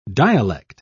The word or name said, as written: dialect